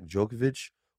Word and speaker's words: Djokovic: The Australian Open winner will be Novak Djokovic or the field? – First of all, great pronunciation of Djokovic.